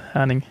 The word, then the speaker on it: Herning: you are 29 years old, defender, born in Herning, in Denmark. That's right. Yes. – And how is it pronounced? – Really good, actually. How he just okay. said it, Herning.